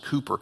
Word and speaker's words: Cowper: William Cooper, and it's spelled C-O-W-P-E-R, but it's pronounced Cooper.